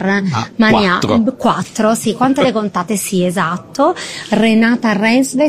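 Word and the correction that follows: Renate Reinsve: si pronuncia così? – Renate Rensve, che ascolterete a breve.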